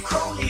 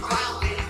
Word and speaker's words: Crowley: And his name is Crowley, it rhymes with holy. It isn't Crowley, that rhymes with Crowley.